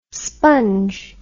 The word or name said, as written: spunge